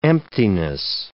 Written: emptiness

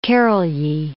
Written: Karolyi

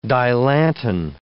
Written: Dilantin

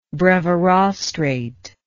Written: brevirostrate

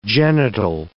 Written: genital

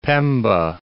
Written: Pemba

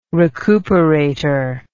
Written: recuperator

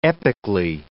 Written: epically

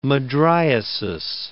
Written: mydriasis

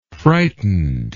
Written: frightenedly

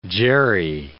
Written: jerry

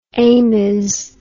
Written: amies

